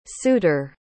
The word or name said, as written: sutor